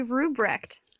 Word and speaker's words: Rubrecht: This is my friend Carly, and I seriously don't know how to say your last name. – Carly Rubrecht. – Rubrecht. – It's pretty hard. Yeah, it looks totally different, doesn't it? – Yeah, is it German? – Wow, first guess, yes.